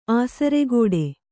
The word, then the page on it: ಆಸರಗೇಡಿ: āsaragēḍi